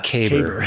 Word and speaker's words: caber: Did I say saber? – You said saber, yeah. – Caber. Caber toss.